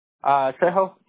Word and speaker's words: Trejo: Trejo, T-R-E-J-O. – T-R-E-J-O. Got it. And Hector, you spell that H-E-C-T-O-R, right? – Yep.